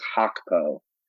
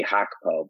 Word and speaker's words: Gakpo: Well, if I'm assuming you're thinking of Cody Gakpo, um, I'm I'm pretty sure it's the proper pronunciation is Hakpo. That's like how the Dutch would say I think G has like a huff, like an H sound. So it would be like Cody Hakpo, but most people just say Gakpo.